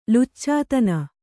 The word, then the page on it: ಲುಚ್ಚತನ: luccatana